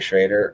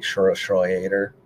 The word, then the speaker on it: Schroeder: And I guess Walker is pronouncing his name wrong this whole time. It's like Jeffrey Schroeder. But he just calls him Jeffrey Schrader or something, but it's like Schroeder. Like he's actually German descent.